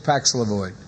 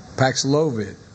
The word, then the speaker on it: Paxlovid: Millions of Americans have used Paxlovid. Paxlovid, excuse me. Paxlovid. I tell you what, I think it's.